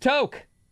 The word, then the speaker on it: toque: T-O-Q-U-E, I think. – Right, but it's pronounced toke, like take a toke. – Take a toke. – Take a toke of that pipe. – Like a T-O-K-E, but with a T-O-Q-U-E.